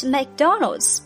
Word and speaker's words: McDonald's: How about McDonald's？